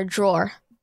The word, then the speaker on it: drawer: It's just saying, it's like door with an R, drawer.